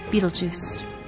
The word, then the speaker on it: Betelgeuse: Western pronunciation has mutilated some of the names. Ibtel Jauza is the origin of the name Betelgeuse. Its meaning is clearer than its pronunciation.